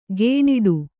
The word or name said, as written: ಗೇಣಿಡು